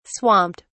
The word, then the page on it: swamped: British English